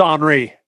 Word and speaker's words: Henri: I call it Henry, but it's not Henry. – It's Henri. – Henri, yeah. It's French. – The H is silent.